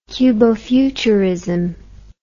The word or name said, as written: cubofuturism